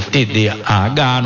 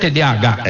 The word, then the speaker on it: TDAH: Nós estamos conversando aqui sobre TDAH, nós estamos falando também, vamos falar... Tô falando certo, TDAH. – É isso. Isso. – Junto, eu falo devagarzinho que a pessoa entender as letras, né?